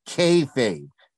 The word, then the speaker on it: kayfabe: Kayfabe.